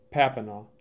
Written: Papineau

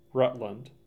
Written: Rutland

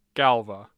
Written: Galva

